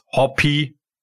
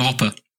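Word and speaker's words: Hoppe: And we've got the American force Hoppy, if that's like that's how he wants to be pronounced, isn't it? – Yeah, exactly that. I mean, it's a tricky one for Germans, isn't it? Because everyone wants to call him Hopper.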